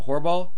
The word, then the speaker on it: Horbol: Horbol. – Horbol, Horbol, like Horbol. – Horrible without the middle syllable, but I always say that I'm not horrible.